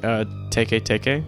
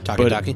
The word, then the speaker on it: teke teke: But it looks like it's spelled like teke teke, like it's T-E-K-E-T-E-K-E. – Uh-huh. Taki-taki.